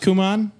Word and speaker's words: Kumon: Is it pronounced Kumon? – Yeah. – Okay. – K-U-M-O-N. – K-U-M-O-N.